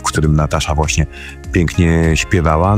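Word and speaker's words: Natacha: To oczywiście Natasha Atlas, chociaż tak spolszczenie mówimy Natasha Atlas, ale pisze się to przez CH, a nie przez S.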